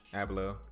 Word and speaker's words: Abloh: Abloh.